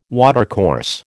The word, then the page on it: watercourse: American English